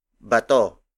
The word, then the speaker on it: bato: Mga is spelled as M-G-A. For example, bato, bato, bato. Bato is spelled as B-A-T-O.